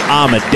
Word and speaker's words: Amade: And I beg your pardon, it's Lee Amade. D-E-E equates to D-A-Y in his pronunciation.